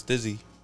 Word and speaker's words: STIIZY: It's it's Stizzy.